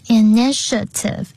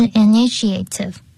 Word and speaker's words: initiative: 独立的作业然后呢 initiative 的发音很重要是 initiative 不是 initiative